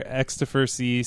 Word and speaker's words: Xtofer: Twitter, XtoferC, so Xtofer, so at XtoferC.